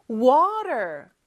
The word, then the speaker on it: water: 근데 미국이나 북미권에서는 요 T가 모음 소리 사이에 껴있을 때는 real처럼 발음이 돼요. 그래서 왜 R 발음할 때는 혀가 어디에도 닿으면 안 된다고 했죠? 얘는 입천장에 살짝 닿았다가 떨어지는 소리예요. water, 이렇게 혀를 굴리는 것처럼 그거를 살짝 한번 해주면 돼요.